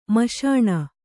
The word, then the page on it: ಮಸಣ: kannada